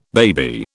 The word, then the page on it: baby: British English